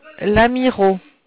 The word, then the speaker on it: Lamiraux: En dessous, le chiffre 2, 2 rue de Lamiro. Lamiro s'écrit L-A-M-I-R-A-U-X.